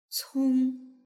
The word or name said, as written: cong